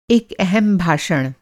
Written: भाषण

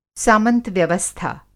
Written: सामंत-व्यवस्था

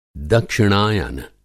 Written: दक्षिणायान

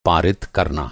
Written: पा

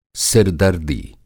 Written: सिर-दर्दी

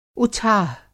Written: उछाह

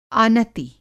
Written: आनति

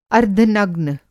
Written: अर्द्धनग्न

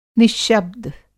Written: निश्शब्द

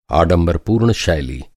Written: आडंबरपूर्ण

